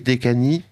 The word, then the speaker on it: Dečani: Vous allez me dire si je prononce bien ou pas. C'est le monastère de Visoki Dečani, Dečani. – C'est presque parfait.